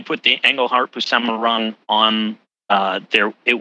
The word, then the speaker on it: Buscema: It is the Englehart Busima, or how do you say his name? Buscema.